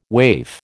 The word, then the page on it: waif: American English